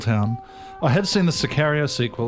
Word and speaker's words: Sicario: I have way more A in mind. – Like A, yeah. Sicario. Sicario. – Yeah, I hang on that a bit.